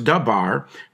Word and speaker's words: davar: He's the King James and the New King James, you know, translated the Hebrew word davar, pronounced dabar, but it They translated it into cause instead of a spoken word.